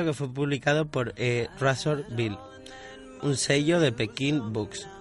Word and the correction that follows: Penguin Books: en 2007. – Penguin Books. – Penguin Books. Siento la pronunciación, pero...